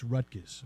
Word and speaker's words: Rutgers: Remember, it's Rutgers. Rutgers. I told you that's how you say it. – Yeah, there's no Rutgers. – T.